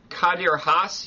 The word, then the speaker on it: Kadir Has: And you teach international relations at Kadir Has University. Is that the way it's pronounced? – Yes, that was perfect pronunciation.